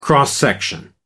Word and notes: cross-section: American English

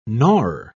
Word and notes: gnawer: British English

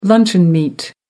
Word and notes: luncheon meat: American English

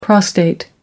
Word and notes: prostate gland: British English

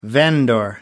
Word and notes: vendor: American English

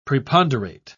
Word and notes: preponderate: British English

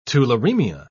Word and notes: tularemia: British English

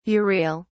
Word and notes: urial: British English

